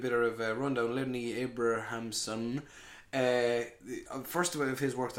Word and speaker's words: Abrahamson: Um, Lenny Abraham's. Am I saying that right? – Abrahamson. – Abrahamson. Abrahamson. God, lovely Irish name that.